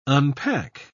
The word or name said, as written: unpack